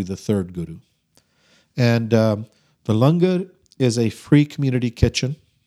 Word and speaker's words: langar: The langar, is that how you pronounce it? – Yep.